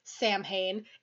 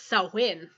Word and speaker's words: Samhain: It is believed to have originated as part of Samhain, which is spelled Samhain, and up until like 2 days ago I thought was pronounced Samhain. It's not. It's Samhain. – Samhain. – Samhain. Irish spelling.